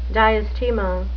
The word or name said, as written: diastema